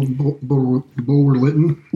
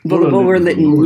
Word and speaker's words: Bulwer-Lytton: Oh, Bulwer-Lytton. – Bulwer-Lytton, yes, indeed. – There was, the W is silent.